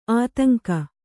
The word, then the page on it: ಆತಂಕ: ātaŋka